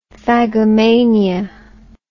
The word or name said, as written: phagomania